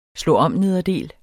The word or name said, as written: slå